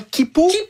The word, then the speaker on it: Quipu: Et si vous pensiez que c'était déjà vertigineux d'imaginer l'Agnakéa, attendez qu'on vous parle de, comment, Kipu, ça s'écrit Q-U-I-P-U.